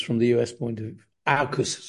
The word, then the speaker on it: AUKUS: Now, as you mentioned, there's a new formation, the acronym AUKUS, is that how it's pronounced? – AUKUS, yeah, AUKUS.